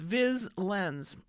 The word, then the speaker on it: Viz Lens: The first topic is Viz Lens. It's spelled as all one word, capital V-I-Z, capital L-E-N-S, Viz Lens.